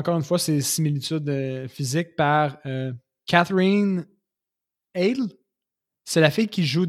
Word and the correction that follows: Heigl: Ah, Catherine Heigl. – Heigl, c'est comme ça qu'on le prononce.